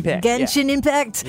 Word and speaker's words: Genshin Impact: Genshin Impact.